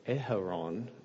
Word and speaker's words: Aharon: Next week, Val will be teaching, but when I'm teaching again, we're going to look at Jesus, Messiah, son of Aharon, which is the Hebrew way of pronouncing Aaron.